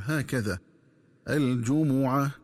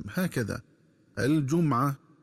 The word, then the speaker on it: الجمعة: ينبغي التنبه للنطق الصحيح لكلمة الجمعة، فتقرأ بضم الجيم والميم هكذا الجمعة، ومن الخطأ قراءتها بضم الجيم وتسكين الميم هكذا الجمعة.